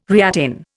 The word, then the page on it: reattain: Indian